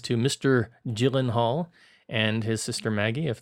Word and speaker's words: Gyllenhaal: He says, I have to correct on this, but Jake Gyllenhaal, as I was saying it last week, is pronounced Gyllenhaal, and it's not with the hard G.